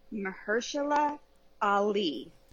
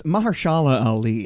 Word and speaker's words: Mahershala Ali: Picture this. This is TTP's pronunciation. – Mahershala Ali. – Mahershala Ali. – Mahershala Ali. – Let's do it one more time. – Mahershala Ali. – Mahershala Ali. – Whoa, wait. Hey, we actually got it right.